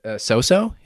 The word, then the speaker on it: Psopso: Yeah, and the dog's real name is So-So. It's spelled P-S-O-P-S-O in real life.